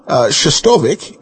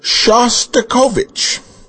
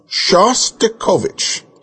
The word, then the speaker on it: Shostakovich: It's not Shostovich, it's Shostakovich. Please do the man the honor of saying his name right. Shostakovich, Shostakovich.